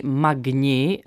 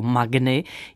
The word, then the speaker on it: Magni: Příjmení Magni, eh, tedy také vyslovováno jako Magny, je z latiny, a to z druhého pádu rodného jména Magnus, které znamená velký.